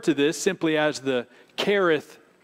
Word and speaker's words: Cherith: Now, Kareth, some would pronounce it Cherith. It is spelled with a C-H as you see it there, but I think the correct Hebrew pronunciation is Kareth, as if it were to say K-E-R.